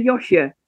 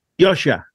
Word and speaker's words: Josje: Okay, so we'll say Yoshua. – Yoshua. And it's spelt, by the way, everyone, it's spelt J-O-S-J-E.